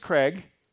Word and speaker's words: Craig: So my name is Craig, which rhymes with, give me something that rhymes with. There you go, Craig Egg.